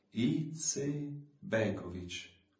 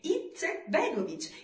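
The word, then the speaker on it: Izetbegović: Izetbegovic. – Guarda che stai sbagliando ancora. Di in modo giusto e con un senso di pace, Izetbegovic, Izetbegovic, Izetbegovic, Izetbegovic, con la T chiara ed esatta nel mezzo.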